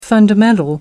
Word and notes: fundamental: American English

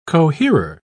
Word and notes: coherer: British English

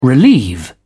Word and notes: relieve: British English

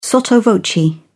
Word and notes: sotto voce: British English

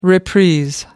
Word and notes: reprise: British English

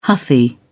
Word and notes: hussy: British English

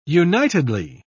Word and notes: unitedly: British English

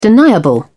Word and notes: deniable: British English

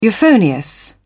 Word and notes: euphonious: British English

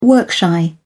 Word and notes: work-shy: British English

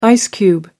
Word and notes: ice cube: American English

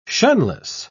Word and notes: shunless: British English

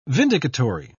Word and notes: vindicatory: British English

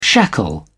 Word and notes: shackle: British English

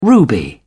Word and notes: ruby: British English

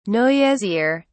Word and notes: noisier: British English

